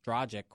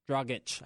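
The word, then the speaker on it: Dragić: They're putting Trey Burke on Dragic, which, again, Dragic, I should say. I've been scolded into pronouncing that correctly.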